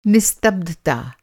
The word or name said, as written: निस्तब्धता